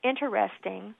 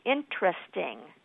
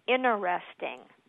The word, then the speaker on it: interesting: You can pronounce that either as four syllables, interesting, or three, interesting. – Mm-hmm. – But what's not correct is interesting, where you leave out that first T.